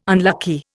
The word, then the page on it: unlucky: Indian